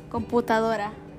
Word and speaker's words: computadora: In Spanish, computer is computadora. – Computer. – Computadora. – I said computer. – Computadora. – Computadora. Computadora.